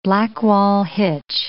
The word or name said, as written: Blackwall hitch